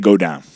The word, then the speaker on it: Godin: Is it Godin or what? – I think it's Godin. – Oh, okay. – That's how you say it in Montreal, but. – Yeah. – It's if you're American, you probably say Godin. But they say go down.